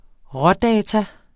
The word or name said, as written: rådata